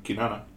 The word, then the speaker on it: Kinana: I feel like I'm not saying that right. – This one, Kinana. – Kinana? Okay.